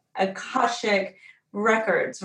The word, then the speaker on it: Akashic Records: So that's another whole episode, but the way that you found the modality of what you do, which is Akashic Records, right? – You said it right.